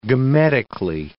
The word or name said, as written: gametically